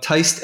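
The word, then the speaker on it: Tiste: Think of the word Teist as I wrote it. It's T-I-S-T-E. Well, it's a long I, and that's why you have the E at the end of it. If you took the E off, it would be Tist.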